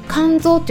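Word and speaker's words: 甘草: あ、一つですねでアダプト源として有名でもう最もね手に入りやすいのは肝臓だと思うんですね肝臓っていうのは甘いという感じの「甘」っていう感じそして「草」という感じでこれ「肝臓」って読むんですね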